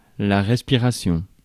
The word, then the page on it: respiration: [ʁɛs.pi.ʁa.sjɔ̃]; France